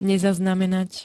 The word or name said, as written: nezaznamenat